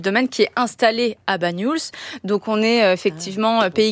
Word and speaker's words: Banyuls: Comment on le prononce? – Banyuls. – Banyuls. – Oui, ouais. C'est ça.